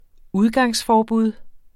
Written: udgangsforbud

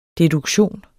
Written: deduktion

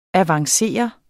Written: avanceret

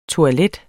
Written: toilettet

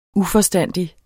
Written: uforstandig

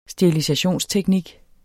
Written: sterilisationsteknik

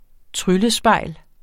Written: tryllespejl

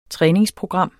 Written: træningsprogram

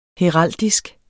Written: heraldik

